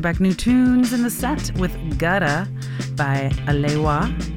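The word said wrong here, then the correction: Alewya: Alewia, there we go. I finally learned how to say it right.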